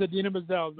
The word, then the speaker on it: Idina Menzel: No, she's Idina Menzel, not I forget.